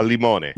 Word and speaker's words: limone: I was like, so you're like, you want to get the linguine, and then I don't know how to pronounce it, limone or limine? – A limone. – Limone.